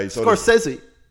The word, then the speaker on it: Scorsese: You know that's not how his name's pronounced. – Oh, no, how? – Because I Scorsese. – Scorsese. Okay. Scorsese. Something like that. And it's him saying it.